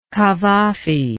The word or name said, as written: Cavafy